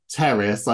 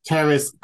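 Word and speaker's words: Pteris: Terrace. I never know. I presume you don't pronounce the P with that. – T-E-R-A-S. – Terrace cretica, and that's Albo lineata.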